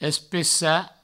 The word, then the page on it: especar: provençal; especa